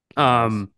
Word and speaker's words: Canales: I mean, I think the Panthers hiring Davis Canales, is that how you pronounce it? Canales. – Yeah. Canales.